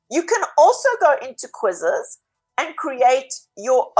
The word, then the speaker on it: Quizizz: So another one is called quizzes. This is spelled Q-U-I-Z-I-Z-Z.